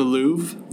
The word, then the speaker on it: Louvre: Did I say that right? The Louvre? – The Louvre? – Yeah.